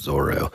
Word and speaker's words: Zoro: And so he wrote it, the English translation is Zolo, but it's pronounced Zoro in the anime and everything.